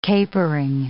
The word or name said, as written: capering